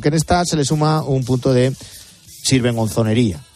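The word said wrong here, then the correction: sinvergonzonería: ¿Lo he dicho bien? – Sí, sin vergonzonería, ¿no?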